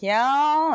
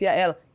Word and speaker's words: Yael: they keep like Yao and I'm like, no, it's Yael.